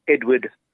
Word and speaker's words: Edward: My email is Edward Kannaih, that's my full name.